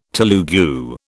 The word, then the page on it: Telugu: British English